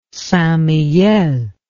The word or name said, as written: St Mihiel